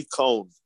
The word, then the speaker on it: Cohn: The name is Roy Cohn. C-O-H-N is pronounced Cohn. Roy Cohn.